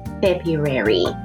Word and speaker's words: February: R を抜いて February だったけどちゃんと R を発音するべきだ